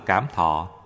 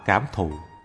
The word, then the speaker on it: cảm thọ: Thọ tức là cảm thọ, cũng đọc là cảm thụ, sanh ra cho sự tiếp xúc giữa tâm và cảnh.